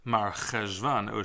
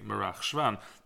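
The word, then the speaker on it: Marcheshvan: October, the eighth month, I guess, was noted and notable, so Merach Shvan would be the eighth month, which would mean that the way we pronounce this name really should be Merach Shvan. And that instead of Mar Cheshvan, it should be Merach Shvan.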